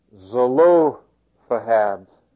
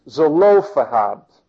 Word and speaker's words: Zelophehad: That's the correct pronunciation. I'm going to go ahead and repeat that for you because I took the time to look this one up. Zelo. Lo is in all caps because that's what you want to pronounce the strongest there. I know there's a correct word for that, but it's escaped me. So it's Zelophehad,